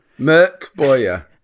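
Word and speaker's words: Mörk Borg: I'm hesitating to correct your pronunciation of Morkborg, Matt. Merk Boyer. Come on. At least try.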